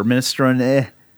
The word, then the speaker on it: minestrone: And, that reminds me, I looked up minestrone. Is apparently the way to do it, or minestrone. You know, that E is supposed to be pronounced.